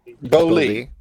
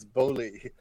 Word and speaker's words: Beaulieu: Is that how you pronounce it? – Beaulieu. Yeah. Beaulieu. We just figured this. I've worked with him for 17 years and I just figured out 2 weeks ago it's Beaulieu, because I said, I'm just in the right time.